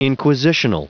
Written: inquisitional

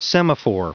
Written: semaphore

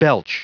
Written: belch

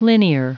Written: linear

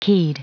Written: keyed

